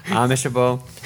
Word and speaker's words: amicable: I'm the person doing what I need to do to make things amicable between people, like for the Dispatch. – Amicable? – Is that what that is? – Amishable. – Amishable. – It's a soft C.